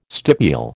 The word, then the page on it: stipule: American English